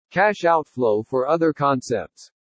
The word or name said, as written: CASH OUTFLOW FOR OTHER CONCEPTS